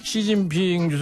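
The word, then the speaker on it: 시진핑 (習近平): 1번 시진핑, 2번 등샤오핑. 야, 원어 그대로 올려놨어. 가스 PD 많이 발전했습니다. 네. 예, 네, 좋습니다. 시진핑 주석은 저 한자를 그대로 쓰면 습근평이죠, 이분은. 응? 그래서 이제 중국말로 읽을 땐 시진핑이고, 등샤오핑은 등소평인데 등샤오핑으로 중국어로 발음하는 것 같더라고요.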